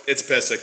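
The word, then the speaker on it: Pysyk: Subtractions, Will Butcher, Cody Eakin, Mark Pizik. Yeah, I'm probably pronouncing that wrong. – No, it's Pizik. You're right.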